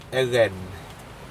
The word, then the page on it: ecen: Aneityum